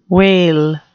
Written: wale

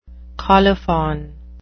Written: colophon

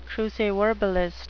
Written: cruciverbalist

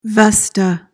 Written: vesta

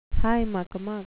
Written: high-muck-a-muck

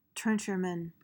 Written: trencherman

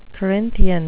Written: corinthian

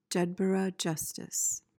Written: jedburgh justice

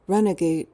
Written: runagate

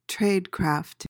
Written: tradecraft

